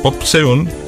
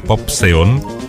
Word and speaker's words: Popxeon: Bueno, muchachos, Popseon, ojo, voy a deletrear, por si acaso, cómo se escribe el nombre artístico, P-O-P-X-E-O-N, Popseon y Venus conforman el dúo anormal,